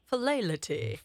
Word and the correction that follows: philately: Philelity? – I think so. – What is... Here we go. Just to be correct. Philately.